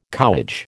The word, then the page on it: cowage: American English